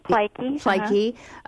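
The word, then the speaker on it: Plaatje: Solomon Plachtchi, is that how you pronounce it? – Plaikie, yes.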